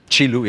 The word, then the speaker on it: Qi Lu: One of your other kind of counterintuitive management moves, I think, is your relationship with Kilu. Qi Lu, yeah. Qi Lu. I, yeah always pronounce that wrong.